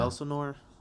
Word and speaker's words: Elsinore: Elsinore? I think I'm saying it right. – Yeah, that's right. I'm pretty sure that's right.